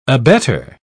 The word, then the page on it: abetter: British English